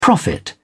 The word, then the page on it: prophet: British English